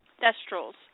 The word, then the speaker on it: Thestrals: Did Thrasyls have wings? Yes, they did, because they flew to the Ministry of Magic on them in Book 5. – Mm-hmm. – Thrasyls? How do you say that? – Thestrals.